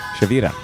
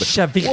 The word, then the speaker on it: Shavira: Oh, you roll the R. – Oh, can you do that? – Shavira. – There you go. Yeah, I couldn't do that. – It's like Myra's Mira.